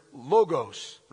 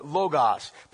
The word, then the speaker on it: logos: It's the Greek word logos, right? Or logos, potato, potato. You can pronounce however you want to.